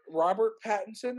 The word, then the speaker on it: Pattinson: Robert Pattinson, am I saying this correctly? – Yes.